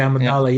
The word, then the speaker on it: Amidala: Padme Amidala. – Okay, Amidala.